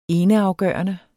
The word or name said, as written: eneafgørende